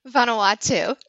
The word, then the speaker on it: Vanuatu: Prior to her work as a grant writer, was a teacher in Texas and Massachusetts and a Peace Corps volunteer in Vanuatu. How do you pronounce that? – Vanuatu.